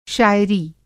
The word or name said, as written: शाइरी